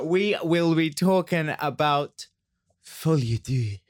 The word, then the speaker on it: Folie à Deux: Well, yeah, it's Folie à Deux.